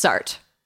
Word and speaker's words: Sartre: How do you say it? – Sartre? – No Sartre? Exit by, that's who right? – Jean-Paul, right? – Jean-Paul Sartre. Sartre. It's Sartre.